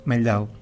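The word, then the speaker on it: Mehldau: El porqué de traje a Meldau, a Meldau, Meldau, según he visto por ahí o he escuchado que se pronuncia, eh, porque he traído a Meldau como cortinas para el día de hoy.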